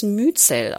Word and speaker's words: Myzel: Myzel spricht man übrigens Myzel aus.